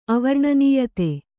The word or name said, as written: ಅವರ್ಣನೀಯತೆ